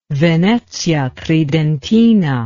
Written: Venezia Tridentina

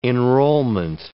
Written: enrollment

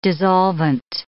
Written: dissolvent

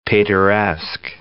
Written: Pateresque

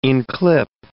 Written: inclip

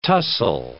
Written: tussle

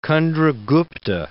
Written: Candra Gupta II

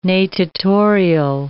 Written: natatorial

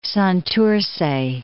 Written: Santurce

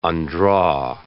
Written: undraw

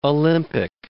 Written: Olympic

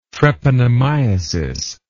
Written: treponemiasis

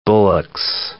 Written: Bullock's oriole

